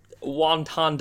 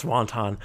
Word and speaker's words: wanton: Whereas Beavis and Butthead, they were raised by TV, but they were still creatures of wanton desires. – Yeah. Pronounced wanton. – Wanton desires. Desires for and of wontons.